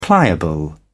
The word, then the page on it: pliable: British English